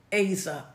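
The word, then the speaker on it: Azer (עֵזֶר): So the word helper is the Hebrew word Azer, A A E E Z E Z E R, but it's pronounced Azer, A Y dash Z E R, Azer, and Azer means in his office of help or helper.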